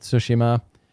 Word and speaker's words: Tsushima: Ghost of Tsushima, which is how you pronounce it. I focused 100 times last night when I was listening to In the Game, them talking about it, and every single character pronounce it Tsushima. So, not Tsushima, Tsushima.